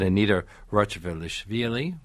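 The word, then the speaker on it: Anita Rachvelishvili: My great thanks to Ildar Abdurazakov, who is Prince Igor, in my mind anyway, and Anita Rochevelishvili. – Great. – Did that okay? – Great pronunciation, yes.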